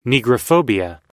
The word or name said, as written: negrophobia